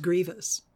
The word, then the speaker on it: Grievous: Except he pronounces his name correctly. He says Grievous.